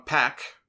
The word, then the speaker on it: Pac: And yes, it is pronounced Pac. Excalibur was yelled at on Twitter a few weeks ago for calling him Pac. And he felt the need to respond. And he said that Pac himself told him that that is the correct way that the name is pronounced.